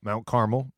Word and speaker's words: Carmel: Well, and Carmel, Mount Carmel, that's the right way to say it.